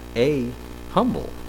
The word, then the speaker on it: humble: Well, the interesting thing is that it used to be humble. Now, in American society, don't come from German background, in American society, the H is pronounced. So it's now a humble.